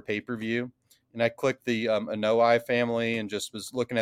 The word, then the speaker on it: Anoa'i: So I believe the pronunciation is Anoa'i. – Anoa'i. So like it's like Hawaii.